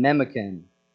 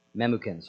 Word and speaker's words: Memucan: and Memucan. Memucan, sorry. Didn't want to mispronounce that.